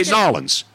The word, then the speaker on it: N'awlins: They say Nolans. – Yeah, that's how they say it. – It's like N-A-W-L-I-N-S. – Yeah. Nolans.